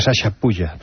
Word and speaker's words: Chachapoyas: Ya, pues se pronuncia Sasha Puya. Pero claro, si te digo eso, vas a decir, no, no, hombre, lo estás diciendo mal, se dice chachapoyas.